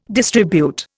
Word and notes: distribute: Indian

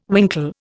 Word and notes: winkle: Indian

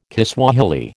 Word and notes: Kiswahili: American English

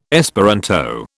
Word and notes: Esperanto: British English

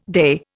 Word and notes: day: Indian

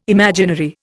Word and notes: imaginary: Indian

